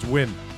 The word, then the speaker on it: Nguyen: Dustin Nguyen, you left 21 Jump Street for this? – Dustin Nguyen. – Whatever. – Yeah, it took me a while to learn to pronounce that last name, too. It's Wynn.